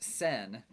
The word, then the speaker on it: sen: So it's normally pronounced sen, but yen, like, sen, and rin that they used to have before hyperinflation destroyed the value of two of them.